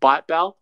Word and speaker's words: ByteBao: but Byte Bell, right? If I pronounce that okay. – That's right. Yep.